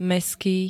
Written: mestský

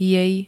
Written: jej

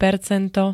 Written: percento

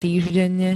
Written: týždenne

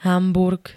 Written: Hamburg

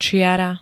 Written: čiara

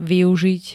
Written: využiť

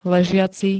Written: ležiaci

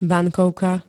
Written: bankovka

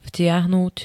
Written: vtiahnuť

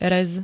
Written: rez

